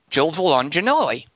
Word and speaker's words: Gilvalangelelli: Arthur Gilvalangelelli says, since you asked last week, my family has always pronounced it Gilvalangelelli, just like I said. He says he's not sure why he never included it before. He's sorry about that. It's phonetically pronounced Gilvalangelelli right there.